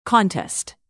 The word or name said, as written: contest